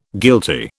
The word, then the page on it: guilty: British English